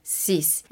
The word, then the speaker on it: six: En el número 6, esta X que se queda al final porque se escribe S-I-X esta X al final se convierte en el sonido de una S prácticamente, cis.